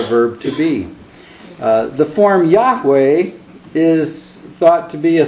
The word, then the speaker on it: Yahweh: But the sort of central official name for God, as I'm sure you all know in the Old Testament, is normally pronounced in Hebrew, Yahweh.